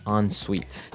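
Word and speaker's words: en-suite: Another note on the spelling here. It's E-N. – Right. – Hyphen S-U-I-T-E. – So even though it's E-N, we pronounce it like an O-N. – Yep. – Ensuite.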